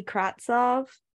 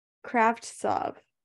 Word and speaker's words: Kravtsov: The Canucks acquired Vitali Kravtsov. Nope, I still didn't say that right. Kravtsov. There you go.